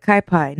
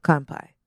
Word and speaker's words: Kanpai: But we ordered from, am I saying this right? Kanpai? – That's how I was there. Yeah, Kanpai. – Or is it Kai Pai? No, Kanpai. – Kanpai. Yeah. K-A-N-P-A-I.